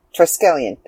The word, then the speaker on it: Triskelion: Okay, Triskelion. I think I said that correctly. Maybe. Maybe not. Let's see. – Triskelion.